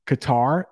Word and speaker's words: Qatar: If you hear that country in English, it is probably pronounced Qatar. That's how we pronounce it. That middle sound for English speakers is too hard for us. So Qatar is the way you'll hear it in English.